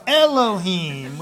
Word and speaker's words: Elohim: Elohim. So close. You got the letters in there, just not the pronunciation. So Elohim.